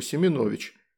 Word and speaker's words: Семенович: Семенович – это мое отчество, а фамилия, если что, Семенович», – добавил он, сделав ударение на букву «О».